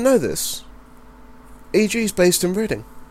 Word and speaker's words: Reading: Yeah, it's spelt Reading, but it's pronounced Reading.